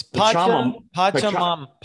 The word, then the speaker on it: Pachamama: It's Pachamama.